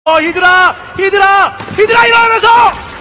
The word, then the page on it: hydralisk: Korean